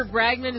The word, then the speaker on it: Bragdon: State Senator Peter Bragdon is joining us now. Did I say that right? – Bragdon. – All right. I knew I could get that. – Get those hard GD syllables, yes.